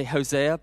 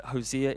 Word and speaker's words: Hosea: Okay, I know some of you say Hosea, but Hosea is the correct way to say it.